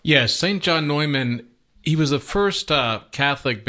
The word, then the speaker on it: Neumann: which is the Feast of St. John Neumann. And I'm understanding that it's actually pronounced Neumann. Is that right? – Yes.